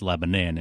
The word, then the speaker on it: Lebanon: And in case people think I'm mispronouncing the name of the town, that's just how they say it in New Hampshire. It's not Lebanon, it's Lebanon. And you can always tell the natives because they know how to say the name of that town.